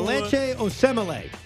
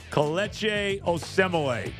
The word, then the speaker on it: Kelechi Osemele: This new Kelechi Osemele. – Kelechi Osemele.